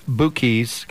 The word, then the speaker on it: Bukis: It's pronounced Bukis, kind of like the Black Keys, but the Bukis.